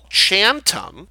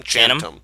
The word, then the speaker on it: Chantum: Chantum, but say it like one word, like Chantum rhymes with Phantom. Like Channing Tatum, Chantum.